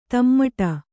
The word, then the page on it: ತಮ್ಮಟ: tammaṭa